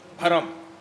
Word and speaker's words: بھرم: کوئی بعید نہیں ہے کہ اس سے تعلق ہو اس کا، بھرم یہی تلفظ ہے جو میں بول رہا ہوں. جو تیسری سواری سرکار کو دی گئی اس کا نام ہے بھرم. بے ہے دو چشمی رے میم اسے کہتے ہیں.